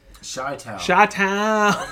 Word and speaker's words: Chi-Town: Chi-Town. The C is silent. – Yeah. – The H is not.